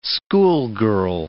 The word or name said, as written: schoolgirl